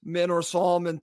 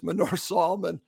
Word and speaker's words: Manor Solomon: And I expect Manor Solomon to start. And I said that wrong. It's Manor Solomon along with Bobby Decadova Reed.